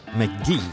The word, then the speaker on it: MacG: Summerlech Teinhai vum äh, kalifornischen Producer vun LA den MacG Eichtlech MK.ge an 2E an den Album den hecht an wie de manselver non noch wie ausgeschwert gëtt pronounced MacG.